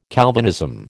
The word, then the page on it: Calvinism: American English